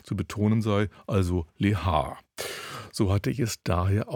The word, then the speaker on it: Lehár: Aus diesem Grunde sei sein Name selbstverständlich auf der ersten Silbe zu betonen, also Lehár, ungarisch Lehor.